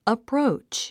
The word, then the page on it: approach: əpróutʃ; アプロウチ